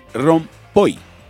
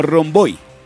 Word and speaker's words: rompoy: Pero bueno, lo cierto es que se debe decir rompoy. – Ajá. – O no romboy. ¿Listo? Se debe decir rompoy, que significa glorieta o rotonda. – Sí. – O sea, que es con... Se escribe R-O-M-P-O-Y.